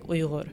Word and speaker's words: Uyghur: But yeah, but in terms of pronunciation, it is like Uyghur.